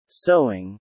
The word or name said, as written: sewing